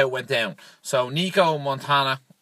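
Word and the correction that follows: Montaño: Is that how you say her name? – Montano.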